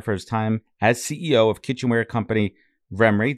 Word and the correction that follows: Remy: Did I say that right? – Remy.